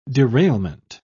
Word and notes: derailment: British English

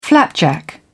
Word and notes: flapjack: British English

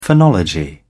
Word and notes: phonology: British English

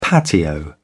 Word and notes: patio: British English

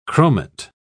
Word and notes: chromate: British English

